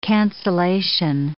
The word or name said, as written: cancelation